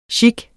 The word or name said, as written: gigue